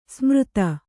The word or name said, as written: ಸಮರ್ಥ